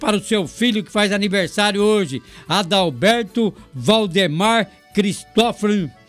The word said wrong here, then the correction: Cristófaro: Cristófano, Cristófaro, falei certo agora? Falei.